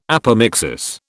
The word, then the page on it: apomixis: British English